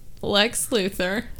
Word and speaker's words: Lex Luthor: Lex Luthor. Did I say that right? – They did.